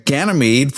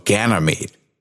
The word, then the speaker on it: Ganymede: Now, did I pronounce that correctly? – You sure did, yes. – That's a tough one. But Ganymede, from what I understand, is the largest and most massive solar system moon that we know of.